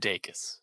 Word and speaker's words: Dacus: I, I, looked up an interview she did, and she said Dacus. So I think right. it's that's right. Uh, I'm going to trust Lucy Dacus on pronouncing her name correctly.